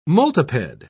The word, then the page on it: multiped: British English